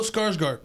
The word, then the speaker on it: Skarsgård: Bill Skarsgård.